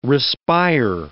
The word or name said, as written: respire